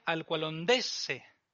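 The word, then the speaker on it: Alqualondësse: si recordáis en una de nuestras primeras lecciones hablamos de que la consonante doble, o sea, una consonante con doble en una sílaba, se robaba el acento. Así que, aunque sea Alqualondë, aquí sería Alqualondëse.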